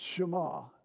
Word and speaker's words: Shema: It's pronounced Shema, S-H-E-M-A, Shema.